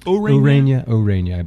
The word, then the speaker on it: Ureña: Ureña, is it Ureña? – I believe that's the correct pronunciation.